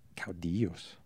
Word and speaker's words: caudillos: Caudillos. – Caudillos? – Yes.